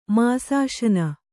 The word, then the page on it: ಮಾಸಾಶನ: māsāśana